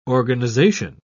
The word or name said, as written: organization